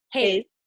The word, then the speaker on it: HAES: So yes, there's this HAES. I don't know how you pronounce it. – Haze.